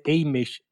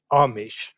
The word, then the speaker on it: Amish: Så skärpning, det heter inte Amish utan Amish.